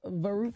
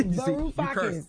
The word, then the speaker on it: Varoufakis: No, shut up. Yanis Faru. – Yeah, exactly. – Varoufakis. – Now, now you on stage. Right. Now they mad because you said their name wrong.